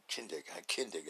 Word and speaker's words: Kindig: Do rest of mods and stuff because, you know, these guys, man, they're doing like Dave Dingig, you know. Oh, did I say that right? Kindig, that's it, Kindig.